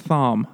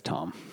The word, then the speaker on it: Thom: Yeah, I want to think it's Thom. I'm gonna say Thom the whole time. – It could be spelt that way. – It is spelt that way, but it's Tom.